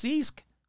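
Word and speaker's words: ceasg: It's C-E-A-S-G. That cannot be how it's pronounced. So let's find out how you pronounce seag. I guarantee it's going to have so many freaking letters. Yeah, no, seriously, I want to find out. Go ahead. Yeah, say. – Seask.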